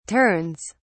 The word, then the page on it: turns: British English